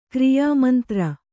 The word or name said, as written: ಕ್ರಿಯಾಮಂತ್ರ